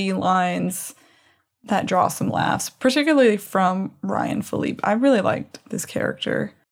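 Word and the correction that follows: Phillippe: I believe it's pronounced Philippi.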